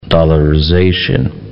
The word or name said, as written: dollarization